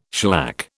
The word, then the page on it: shellac: British English